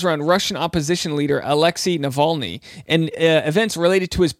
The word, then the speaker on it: Navalny: But last night I watched Navalny. Am I pronouncing that right? No, Navalny.